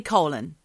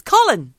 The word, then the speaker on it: Colin: That was a new one at the time for Colin. – I just can't bring myself to say colon. – No, don't say colon. It's Colin.